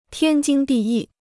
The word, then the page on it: 天经地义: tiān jīng dì yì